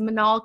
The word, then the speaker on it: Minal: So I introduce myself as Minal because I'm M-I-N-A-L, but my real pronunciation is Minal, but I don't like it. So I always say Minal.